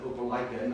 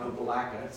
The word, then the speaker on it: Opelika: And I learned to pronounce it Opelika, not Opelika, so I appreciate that as well.